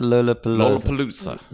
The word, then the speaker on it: Lollapalooza: Lola Palooza.